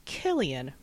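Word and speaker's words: Cillian: Moving on, I think I'm going to pick my Invisible Man, and I choose to go with pronounced Killian Murphy, hard C sound,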